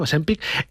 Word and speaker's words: Ozempic: Estos medicamentos como Ozempic, que ahora sí lo digo bien, Ozempic, salieron al mercado para tratar no la obesidad, sino la diabetes.